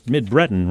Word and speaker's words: Mid-Breton: Did I get that, say that right? Mid-Breton, right? Yes, that's right.